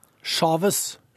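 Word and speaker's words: Chávez: Som mange sikkert har hørt nå, så døde altså presidenten i Venezuela, Hugo Chavez, eller Chavez, litt usikker. Vi kan jo spørre eksperten som er med oss med en gang, Joar Hoel Larsen. Hvordan uttaler man et navn på den mannen? – Chavez.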